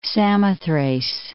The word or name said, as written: Samothrace